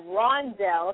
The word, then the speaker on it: Rondell: Is it Mondell? – Yes. – Spell it for me. – It's R-O-N-D-E-L-L. – Oh, Rondell. Okay, Rondell.